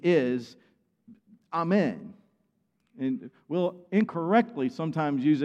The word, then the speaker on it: amen: Another Hebrew word, transliterated, amen. The way it's pronounced in it supposedly is amen. If you want to hear the actual Hebrew, amen, amen. Jesus would say amen, amen.